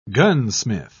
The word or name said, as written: gunsmith